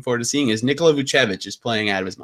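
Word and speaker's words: Vučević: Yeah, Vucevic. Yeah, I nailed that. I'm looking at his pronunciation on B-ball ref.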